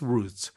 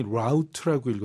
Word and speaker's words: route: And several public bus routes. 이 버스 노선에도 아주 가깝다. 이런 얘기죠. 아까는 이제 원어민이 route라고 읽었어요. 이 route, route 모두 표준 발음입니다.